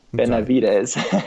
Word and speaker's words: Benavides: This Francis, uh, Franciscan missionary named Alonso de Be- uh, I'm going to butcher his name Benavides.